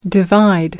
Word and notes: divide: American English

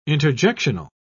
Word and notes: interjectional: British English

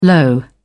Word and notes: low: British English

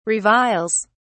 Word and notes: reviles: British English

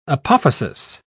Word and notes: apophasis: British English

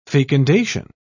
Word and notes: fecundation: British English

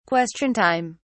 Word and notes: question time: British English

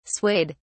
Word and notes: swayed: British English

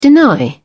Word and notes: deny: British English

